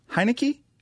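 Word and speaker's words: Heinicke: Is Tyler Heineke? – That's how you say it.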